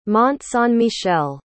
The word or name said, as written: mont-saint-michel